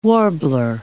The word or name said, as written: warbler